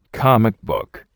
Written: comic book

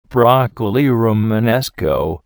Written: broccoli Romanesco

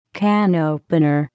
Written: can opener

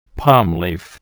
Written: palm leaf

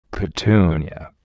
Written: petunia

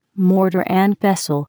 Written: mortar and pestle

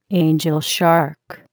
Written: angelshark

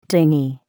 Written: dinghy

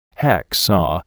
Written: hacksaw

